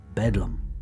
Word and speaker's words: Bedlam: The Bethlehem Hospital, better known by the name the Bethlehem Hospital, which came to be pronounced as Bedlam.